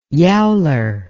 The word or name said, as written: yowler